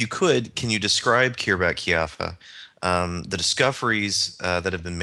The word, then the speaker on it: Khirbet Qeiyafa: Well, let's shift from that to something much more specific, and the particular dig site that you've worked with, Luke, Khirbet Qeiyafa, I believe that's... – Yes, Khirbet Qeiyafa, yep.